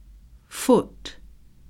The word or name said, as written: foot